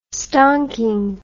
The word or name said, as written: stonking